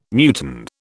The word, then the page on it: muton: British English